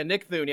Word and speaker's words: Nick Thune: The lead guy, Nick Thune, am I pronouncing that right? – Yeah, Nick Thune.